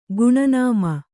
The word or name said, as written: ಗುಣನಾಮ